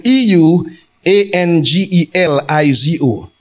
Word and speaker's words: euangelizo: The Greek word is spelled U E, sorry, E U A N G E L I Z O euangelizo.